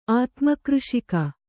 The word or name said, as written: ಆತ್ಮಕೃಷಿಕ